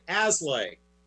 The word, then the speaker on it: Aslay: Last name is spelled A is an apple, S is in Sam, L A Y Aslay. Dot com and so it's as and lay.